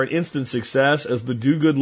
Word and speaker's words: Dogood: actually spelled D-O-G-O-O-D is one word, but pronounced Duguid.